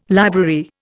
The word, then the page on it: library: Indian